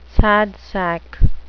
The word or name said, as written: sad sack